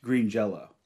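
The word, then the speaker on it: Green Jellÿ: It's pronounced green jello. – I always say green jello. I don't care. – It's green jelly, but pronounced green jello.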